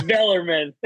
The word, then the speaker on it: Bellarmine: Um, I'm pretty sure I've heard you guys talk about this, but are Bellarmine Is that the proper pronunciation? Blarmy. okay. – uh, it's Bellerman.